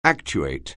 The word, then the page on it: actuate: anglais